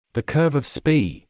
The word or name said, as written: the curve of Spee